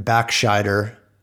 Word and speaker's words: Backscheider: And a Frenchman, Backscheider. Did I get that right? Am I going to be? Yeah. – Nope, that's how I would pronounce it.